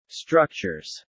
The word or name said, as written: structures